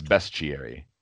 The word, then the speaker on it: bestiary: I was saying beast. I've always said bestiary through my whole life, and there's no A in there. Yeah. So, like, yeah, it's bestiary.